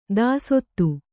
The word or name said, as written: ದೋಸ್ತ